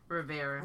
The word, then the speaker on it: Rivera: not Riviera. Rivera. Rivera, excuse me.